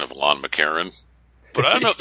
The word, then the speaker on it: McEachern: You know, there are a lot of names like that, but it's spelled McEachern, E-A-C-H-E-R-N. Even at the end, we say McArron. It's like there's nothing between the R and the N.